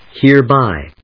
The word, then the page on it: hereby: hère・bý